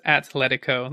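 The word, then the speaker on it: Atlético: Yeah, it's not Atletico, it's Atletico. There's no H in Atletico, Atletico Madrid.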